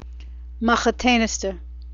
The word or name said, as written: machatainisteh